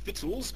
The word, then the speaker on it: victuals: The old-fashioned word here is confusing twice over for the modern reader. First, it's pronounced victuals, and second, it means food.